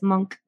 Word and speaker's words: Munch: En el último capítulo que escuché de ustedes, que hoy que estamos grabando, salió el de Edvard Munch. Me acabo de enterar cómo se pronuncia. – Edvard Munch, sí. Pero bueno... Como es noruego, pues la forma correcta de decirlo es Monk.